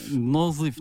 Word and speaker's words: Nazif: Nazif. Macam mana ah. pronunciation dia? Nazif. Nun, zot, ya, fa.